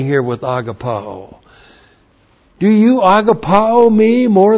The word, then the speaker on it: agapao: And the word he uses there is agapao, and you're all in. You understand exactly what is being said here with agapao, A-G-A-P-A, long O.